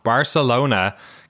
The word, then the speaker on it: Barcelona: Barcelona, Catalunya.